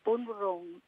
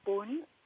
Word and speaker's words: Boonwurrung: Yeah, we're the Boonwurrung people, sometimes called Boonwurrung because of the way the colonists wrote it, yep. – Pronounced it. But we know it's Boon, and Wurrung is a common word for all the Kulin nations in our...